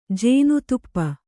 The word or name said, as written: ಜೇನುತುಪ್ಪ